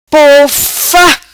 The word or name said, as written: Pofa